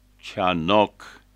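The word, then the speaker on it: Chanok (חנוך): Su nombre, que en hebreo se pronuncia Chanok, significa iniciado o dedicado, que es la misma raíz para Chanukha o Hanukkah, la fiesta de la dedicación.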